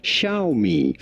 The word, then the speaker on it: Xiaomi: This word is pronounced in Chinese Xiaomi.